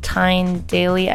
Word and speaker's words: Tyne Daly: With Tyne Daly.